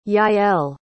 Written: yael

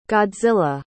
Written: godzilla

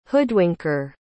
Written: hoodwinker

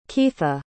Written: keitha

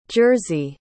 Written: Jerzy

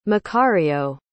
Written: macario

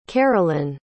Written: caralynn

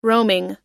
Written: roaming

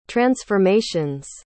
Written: transformation's

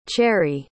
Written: cherri

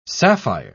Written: sapphire

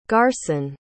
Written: garson

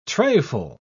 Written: trayful

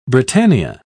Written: britannia

